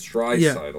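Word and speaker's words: Draisaitl: I need to teach you how to say his name. It's Drysdale.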